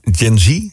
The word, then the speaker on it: Gen Z: En toen heb ik op een gegeven moment aan zo'n Gen Z. Zeg ik dat goed zo? Ja, hè? – Ja.